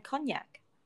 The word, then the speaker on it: konjac: And cognac. – And cognac. Oh. – Not cognac as in the alcohol. Cognac as in the Japanese version of that kind of tasteless gel-like substance. – So it's spelled K-O-N-J-A-C. – Yes, or J-A-K. – Or J-A-K.